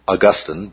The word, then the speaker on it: Augustine: One of the things that Augustine, and sometimes it's pronounced Augustine,